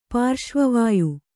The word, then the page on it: ಪಾರ್ಶ್ವವಾಯು: pārśva vāyu